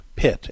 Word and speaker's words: Pitre: It's by Brant Pitt, and Pitt is spelled P-I-T-R-E.